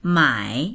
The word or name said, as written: mai3